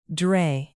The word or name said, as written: dray